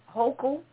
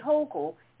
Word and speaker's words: Hochul: And the Lieutenant Governor, Kathy Hochul, I believe that's how she pronounces it, Kathy Hochul is the next in line, and she will end up being the first female governor of New York.